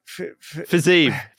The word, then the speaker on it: Fiziev: Fiziev. Well, did I say it wrong? – Fiziev.